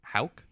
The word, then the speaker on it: Houck: Yeah, there's like two guys on that bullpen I trust, and it's Whitlock and Houck. Is that how you say it? – Yeah. – H-O-U-C-K.